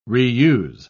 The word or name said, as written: reuse